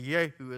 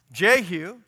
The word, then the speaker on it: Jehu: It's no J's in the language. You say Yehu is the right way to pronounce it.